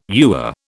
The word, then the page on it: ewer: British English